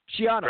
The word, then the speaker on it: Schiano: Schiano.